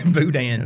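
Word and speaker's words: Bolduan: How do you say that? Boudin? – Yeah. Sounds right. – She pressed Boudin. – Boudin. – That's right. I'm hungry now.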